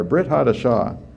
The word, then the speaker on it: Brit Hadashah: Today we refer, we have the New Testament, and I've been saying Brit HaKodesh, but it's actually Brit Hadasha. Brit Hadasha. Am I still saying that wrong? Am I? Brit Hadasha. Hadashah, I'm sorry, Brit Hadashah.